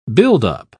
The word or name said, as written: buildup